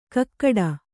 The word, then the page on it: ಗೂಗಿಡು: Kannada